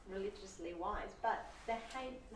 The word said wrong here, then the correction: Hymen: is that how you pronounce it? – Hymen, yeah.